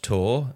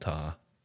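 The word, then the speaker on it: Tár: Oh, is it pronounced Tor? – I just wanted to see if I could get you to say it in the same way that I did. I think it's called Tar.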